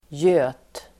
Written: göt

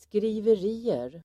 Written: skriverier

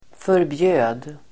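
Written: förbjöd

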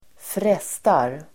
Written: frestar

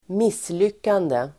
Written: misslyckande

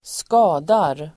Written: skadar

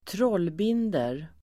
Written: trollbinder

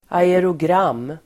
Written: aerogram

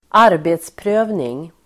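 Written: arbetsprövning

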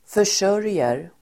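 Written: försörjer

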